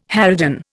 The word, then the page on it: harridan: Indian